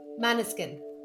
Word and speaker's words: Måneskin: I was going to say, like, I thought it was like Mannequin, like Måneskin. – It looks like Mannequin. It looks like Mannequin. – On the radio today, they were like, Måneskin.